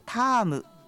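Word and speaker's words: 田芋: タームというのはタロイモというか田んぼの芋って書くんですけど、タームと読むんだそうですよ。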